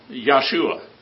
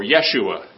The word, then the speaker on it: Yeshua: And they also teach that we have to call Jesus Christ Yahshua or Yeshua. It's pronounced different ways.